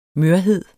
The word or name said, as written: mørhed